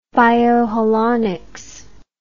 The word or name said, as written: bioholonics